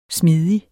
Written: smidig

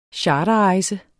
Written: CHARTERREJSEN

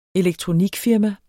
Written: elektronikfirma

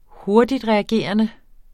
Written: hurtigtreagerende

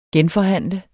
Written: genforhandle

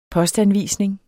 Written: postanvisning

